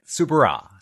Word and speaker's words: Soubirous: Local peasant girl, Bernadette Soubirous, saw an apparition of the Virgin Mary.